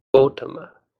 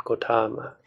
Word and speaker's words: Gotama: So here the stress goes on the O. The word is pronounced Gotama, not Gotama.